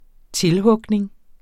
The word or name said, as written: tilhugning